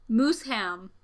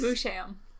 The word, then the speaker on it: Moosham: I hope I'm pronouncing that right. It's spelled like Moosham, so. – Mooseham. – Mooseham? Is it Mooseham? – No. – Oh. This, okay. Anyways. – Moosham.